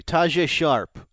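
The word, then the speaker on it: Tajaé Sharpe: Tajay Sharp.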